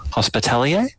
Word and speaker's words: Hospitalier: Hospitalier? – Is that the correct pronunciation? Yeah. Hospitalier, okay.